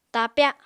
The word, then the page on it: tapya: Apatani